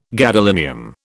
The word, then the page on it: gadolinium: British English